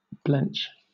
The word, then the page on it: blench: British English